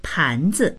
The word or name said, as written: pan2zi